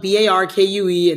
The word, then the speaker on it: Barkue: But my name is spelled Barkue, and that's how you find me on Instagram.